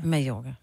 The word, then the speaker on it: Mallorca: Siger Mallorca eller Mallorca? – Mallorca. – Men hvorfor? Det staves jo Mallorca. Men det udtales Mallorca.